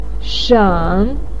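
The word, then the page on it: shen: tone 3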